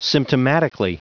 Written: symptomatically

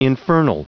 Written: infernal

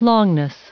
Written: longness

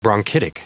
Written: bronchitic

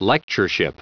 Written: lectureship

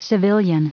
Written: civilian

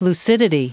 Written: lucidity

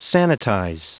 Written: sanitize

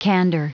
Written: candor

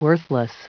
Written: worthless

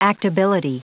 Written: actability

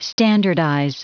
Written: standardize

